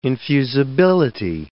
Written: infusibility

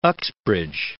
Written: Uxbridge

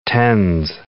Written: TENS

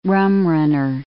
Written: rumrunner

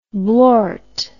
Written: blort

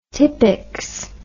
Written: Tippex

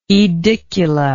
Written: aedicula

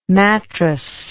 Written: matrass

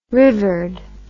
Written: rivered

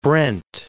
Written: Brent

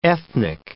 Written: ethnic

